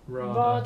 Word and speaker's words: Radha: Ra- Radha. Radha.